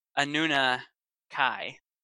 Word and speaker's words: Anunnaki: Well, first of all, let's correct your pronunciation. It's Anunnaki, because it's Jerry, you just got checked.